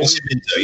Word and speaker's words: Nascimento: Then I got Nascimento. Is that pronounced right? 92. – Nascimento, yeah.